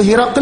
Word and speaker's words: هرقل: ഇപ്പൊ ഹിറക്കൽ എന്ന് പറയുമ്പോൾ ഈ വാക്കിന് രണ്ട് ഉച്ചാരണ രീതിയുണ്ട്. ഒന്ന് ഹിറക്കൽ, ഹായിന് കസ്റും റായിന് ഫത്ഹും ഖാഫിന് സുക്കൂനും. രണ്ടാമത്തെ ഉച്ചാരണ രീതി ഹിർക്കിൽ, റായിന് സുക്കൂനും ഖാഫിന് കസ്റും.